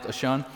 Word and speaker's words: Ashan: which I'm having to work really hard because I've always heard that pronounced a different way, but I read some earlier in the week. It's pronounced Ashan.